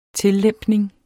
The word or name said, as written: tillempning